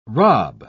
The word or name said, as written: rob